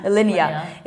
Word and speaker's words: Alinea: I've pronounced it wrongly all the way. Alinea. Alinea. – Alinea.